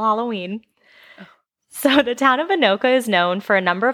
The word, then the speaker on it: Anoka: So on the main website for Anoka, and I looked up how to spell, like how to say this, so I know this is right. Anoka. – I heard it, and it's right.